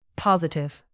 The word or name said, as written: positive